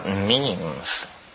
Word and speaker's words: meme: Yeah, well, we've talked here, not this year, but last year, about memes. You know what memes are? Memes are the smallest units of a concept. And they're like genes. The word is deliberately constructed to rhyme with gene.